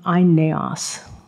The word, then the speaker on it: Aeneas: Peter meets a man by the name of Aeneas. Again, that's the Greek pronunciation, who had been paralyzed for 8 years and bedridden, unable to work or even support himself or his family.